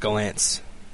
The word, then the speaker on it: Gollancz: Okay, first of all, how do you pronounce Galansk? Is it Galansk or? – I, have always pronounced it Gollancz.